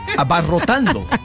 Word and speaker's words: abarrotando: Abarratando. – Abarrotando. – Gracias.